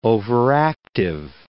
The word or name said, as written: overactive